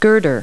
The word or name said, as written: girder